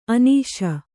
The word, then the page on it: ಅನೀಶ: anīśa; kannada